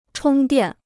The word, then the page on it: 充电: chōng diàn